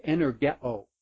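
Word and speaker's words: energeo (ἐνεργέω): Where translated effectually worked is the word that would be pronounced energeo.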